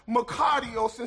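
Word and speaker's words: makarios (μακάριος): It's pronounced makarios.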